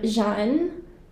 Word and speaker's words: Jeanne: For so long, people have known me as Jeanne. And this year, I really wanted to stay in my power and find that boundary to say my name correctly, introduce my name correctly to everybody. And so I am Jeanne.